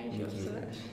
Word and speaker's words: Nicki Minaj: Mickey Minaj, Nicki Minaj.